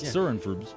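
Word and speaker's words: Soronprfbs: When we meet them, Frank and his band, the unpronounceable Sorenfrubs. – Sorenfrubs. – Sorenfrubs.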